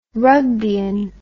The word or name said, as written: rugbeian